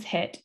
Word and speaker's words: HIIT: If you ever see something that's spelled out H I I T, pronounced as HIT, that is high intensity interval training.